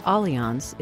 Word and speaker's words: Allianz: the company pronounced Allianz is committed to helping you get there.